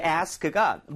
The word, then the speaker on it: ask: 앞에 a에다 강세를 꽝. 그래서 sk 이게 굉장히 빨려 들어오는 수준으로 짧게 발음이 될 겁니다. 오케이, okay, so ask 하지 마시고요. Ask. 오, Ask. – 훨씬 좋아요.